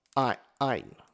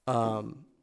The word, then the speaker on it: Ayin: I will get I'm gonna get the name right. I have to get it. Again, I'm terrible pronouncing them. Ayn. So A-Y-I-N. – Okay. – Ayn.